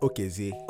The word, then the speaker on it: Okezie: Well, yes, uh, but it's pronounced Okezie.